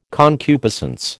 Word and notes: concupiscence: American English

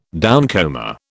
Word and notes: downcomer: British English

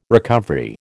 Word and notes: recovery: American English

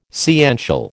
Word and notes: sciential: American English

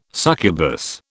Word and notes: succubus: British English

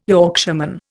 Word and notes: Yorkshireman: Indian

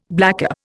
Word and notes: blackcap: Indian

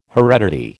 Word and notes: heredity: American English

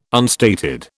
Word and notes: unstated: British English

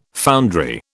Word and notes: foundry: British English

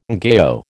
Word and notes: ngaio: American English

